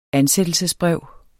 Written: ansættelsesbrev

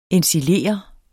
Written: ensilere

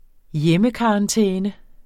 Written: hjemmekarantæne

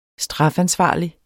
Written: strafansvarlig

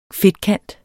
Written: fedtkant

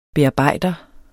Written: bearbejder

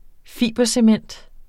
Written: fibercement

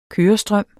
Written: kørestrøm

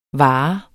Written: vare

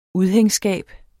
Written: udhængsskab

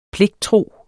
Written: pligttro

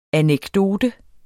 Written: anekdote

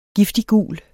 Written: giftiggul

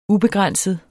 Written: ubegrænset

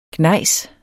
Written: gnejs

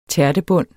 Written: tærtebund

